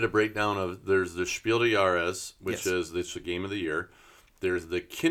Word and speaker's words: Spiel des Jahres: And she was bringing up the fact that there are very few women designed games in the Spiel des Jahres. Did I pronounce that correctly? – No. Spiel des Jahres. – Jahres, excuse me.